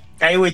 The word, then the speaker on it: Kaiwi: Porque completaste el cruce de Kaiwi, ¿se dice así? – Kaiwi Channel, sí.